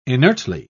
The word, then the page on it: inertly: British English